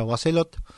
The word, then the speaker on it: Boisselot: Este es un piano Boisselot, se escribe, que se pronuncia Boisselot.